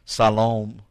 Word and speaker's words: Siloam: I should say Siloam is the way you pronounce properly. That O is long vowel, not short vowel. Siloam.